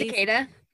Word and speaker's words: cicada: it's like Cicaca, it's that bug that's loud and makes a lot of noise. – Cicada.